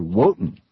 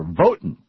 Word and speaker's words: Wotan: In Northern Europe, ancient Germanic people tied fruit and attached candles to evergreen tree branches in honor of the god Wotan, or Votan, as they would pronounce it there.